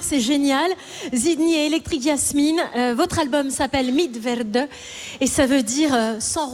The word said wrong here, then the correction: Mit Werde: Oui, alors ça se prononce Mit Werde, pardon.